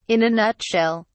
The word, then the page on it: in a nutshell: British English